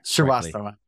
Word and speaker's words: Srivastava: Did I say it right? I always can never really say it. – Srivastava.